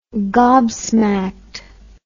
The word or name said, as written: gobsmacked